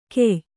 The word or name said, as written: ಖೇಯ